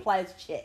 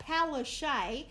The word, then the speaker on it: Palaszczuk: She really, Plaszczuk is her actual name, if you want to get fucking technical. Her actual name Plaszczuk. – Is Anastasia Plaszczuk. That's how you pronounce her name. How the fuck she got Palaszczuk out of Plaszczuk is beyond me.